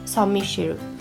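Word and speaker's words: Saint-Michel: 語らせてください歴史背景としては言い伝えになってくるんですけれどもモン・サン・ミッシェルから程近い町の司教だったオベールさんの夢に大天使ミカエル、まあ、フランス語の発音でサン・ミッシェルが現れてであの岩山に聖堂を建てよと命じられたそうなんですね。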